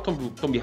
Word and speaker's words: Tomb: E é um jogo gostoso que mistura elementos de Uncharted, que mistura elementos de Tomb Raiders antigos. Inclusive, eu sempre falava Tomb Raider quando eu era pequena. – É, e os produtores falaram que é Tomb Raider, é U que se pronuncia.